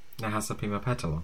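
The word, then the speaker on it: Nahasapeemapetilon: Nahasa Pima Petalo.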